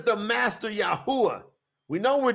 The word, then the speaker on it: Yahuwah: It's Yud, hey, uah, hey. So, in Hebrew, that is pronounced, when you say it in the English, Yahuwah, Yahuwah.